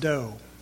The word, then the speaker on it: Dow: If you want to look up his name, it looks like Dow, D-O-W, but it's pronounced Doe.